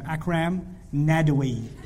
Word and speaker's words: Akram Nadwi: Write that name down. Akram Nadwi. A-K-R-A-M. Akram Nadwi. That's how you say it in Siri. Nadwi, N-A-D-A-W-Y, or N-A-D-W-I also.